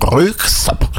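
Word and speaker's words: Röyksopp: Da gibt es eine Band, könnte vielleicht der eine, also Jürgen kennt sie bestimmt, Röskop oder Röksop, werden sie geschrieben und ausgesprochen, Röksop.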